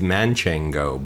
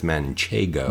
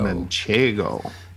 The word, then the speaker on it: Manchego: I always want to say it's Manchego, but it's not. It's Manchego. – Manchego. – Yeah. Yep. I want to put an extra N in there for you.